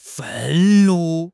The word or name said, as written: 愤怒